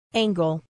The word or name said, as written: angle